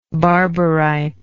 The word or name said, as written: barberite